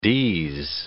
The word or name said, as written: ds